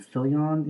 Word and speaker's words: Fillion: I think it has Nathan Fillion in it.